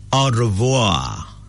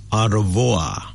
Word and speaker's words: Au revoir: We have a French word, A-U, and then R-E-V-O-I-R. Au revoir. That's how it's pronounced. Au revoir.